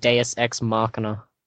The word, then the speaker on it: Deus Ex Machina: Our next one we got from Deus Ex, I think it's supposed to be Machinima. I've heard of that Machinima. – I think it's pronounced Deus Ex Machina.